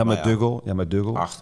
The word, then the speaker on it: McDougal: McDougal. – Ja. – Spreek je dat zo uit? – Ja, met Dougal.